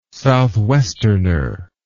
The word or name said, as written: southwesterner